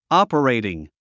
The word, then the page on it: operating: ɔ́pərèitiŋ; オパレイティン